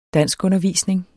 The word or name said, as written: danskundervisning